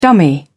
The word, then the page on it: dummy: British English